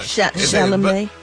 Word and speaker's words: Chalamet: Chalamet. – Oh, that's how you say it. – Chalamet. – Chalamet. – You was close, though. You say. – You ever thought about going back? You ever thought about going back to kindergarten? – It's like Chevrolet. Oh, not Chevrolet.